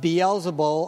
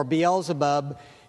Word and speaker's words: Beelzebub: Beelzebul or Beelzebub. It's pronounced in different ways, in different contexts, but it's referring to the evil one.